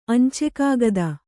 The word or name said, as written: ಅಂಚೆಕಾಗದ